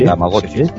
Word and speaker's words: Tamagotchi: No sé si os acordáis de los Tamagotchis, si sí, lo he dicho bien, sí, Tamagotchis.